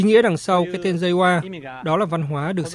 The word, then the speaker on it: Reiwa: Như vậy, bắt đầu từ 0 giờ ngày 1 tháng 5 tới, triều đại mới ở Nhật Bản có niên hiệu Reiwa, theo phiên âm Hán Việt có thể đọc là lệnh hòa hoặc linh hòa.